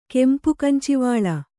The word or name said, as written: ಕೆಂಪುಕಂಚಿವಾಳ